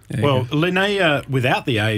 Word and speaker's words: Linnaea: So it'll be so the winery is spelled Linnaea. – Almost, it's Linnaea.